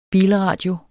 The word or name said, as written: bilradio